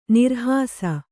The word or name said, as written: ನಿರ್ಹಾಸ